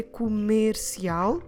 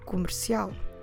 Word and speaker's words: comercial: So I don't need to say comercial, that's not correct. You really need to ignore it, like if it's not even there. So we say comercial, comercial. Our stress is at the end, syllable al, comercial.